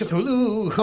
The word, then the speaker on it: Cthulhu: Chululhu dice? – Cthulhu. – On this? Cthulhu? How do you say Cthulhu. – It? – Cthulhu.